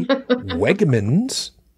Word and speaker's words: Wegmans: And because they were freshly delivered to our local Wegmans, yes, I pronounced it correctly, Wegmans.